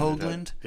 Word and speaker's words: Hogland: Hoagland. Yeah. Hogland. Hoagland. – Yeah, it's spelled Hogland, but the O is long.